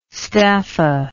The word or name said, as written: staffa